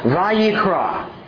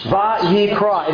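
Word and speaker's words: Vayikra (ויקרא): It is literally pronounced Vayikra. Va-yikra.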